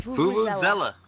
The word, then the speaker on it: vuvuzela: Oh, I can't pronounce that. – Mighty Vuvuzela. Vuvuzela. – Vuvuzela. Okay. Thank you. I will never get it wrong again.